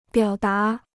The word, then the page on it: 表达: biǎo dá